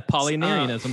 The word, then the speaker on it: Apollinarianism: And then I'm going to try to pronounce this, Apollinarianism. – Yeah, Apollinarianism.